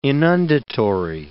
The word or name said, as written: inundatory